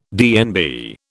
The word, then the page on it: DNB: British English